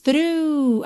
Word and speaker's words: through: Não é difícil mesmo de se pronunciar, mas a escrita dela é T-H-R-O-U-G-H. Então, atenção, só para você fazer essa equivalência, tá? Through, through. Depois do U já acabou o som.